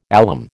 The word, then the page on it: alum: American English